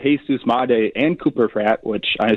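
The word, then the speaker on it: Jesús Made: Jesus Madé. I'll stop saying the whitest pronunciation ever to his name. Thank you, both of you, on that.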